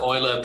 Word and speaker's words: Euler: Bryce, I feel like there was a great gag there from Aaron that went over the top of your head about how do you mix oil and crypto with the Euler pronunciation.